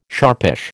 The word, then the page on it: sharpish: American English